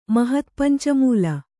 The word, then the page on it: ಮಹತ್ಪಂಚಮೂಲ: mahat panca mūla